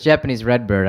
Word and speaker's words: Japanese Redbird: Japanese record. – Everyone says it's Japanese Redbird. I gotta start pronouncing that clearer.